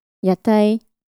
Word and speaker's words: Yatai: Yatai, floats. It's the same pronunciation as the food stalls.